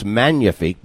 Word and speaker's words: magnifique: Susie told me it's not magnifique, it's magnifique because that is French. You don't pronounce the G.